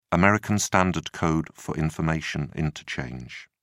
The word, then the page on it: American Standard Code for Information Interchange: British English